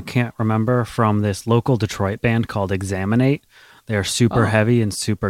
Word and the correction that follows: Exanimate: Or Exanimate is how you say their name. It's Exanimate.